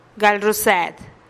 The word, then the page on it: gal ruused: TdVZ